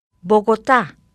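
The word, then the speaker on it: Bogotá: Некоторые слова имеют особое ударение, которое показывается особым значком ударения. – Богота.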